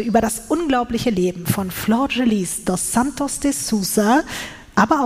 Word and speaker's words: Flordelis: Ich weiß, es wird auch vielleicht ein bisschen komisch, weil der Name wird anders geschrieben, als er gesprochen wird. Also er wird Flor Delis oder Flor Delis geschrieben, aber Flor Gelis ausgesprochen.